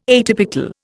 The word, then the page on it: atypical: Indian